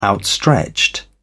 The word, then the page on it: outstretched: British English